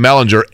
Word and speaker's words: Ehlinger: Ehlinger, right? Is it Ehlinger or Ehlinger? Gur hard hard G.